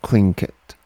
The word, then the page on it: Tlingit: English